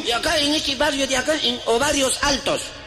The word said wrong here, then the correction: Barrios Altos: Oye, oye, serrano, no se dice Ovarios Altos. – ¿Qué, no? – Son los barrios altos.